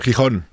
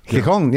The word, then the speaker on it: Gijón: Yeah, in Gijón, is that how you say it? – Gijón. – Gijón, yeah.